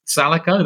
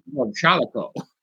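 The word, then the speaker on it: Shalako: It's actually called, it's pronounced Salico. That's just Sean Connery said it. Shalico.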